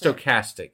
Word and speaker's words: stochastic: Okay, go ahead and read that definition and tell me how to pronounce it. – So it's stochastic is what you were looking for.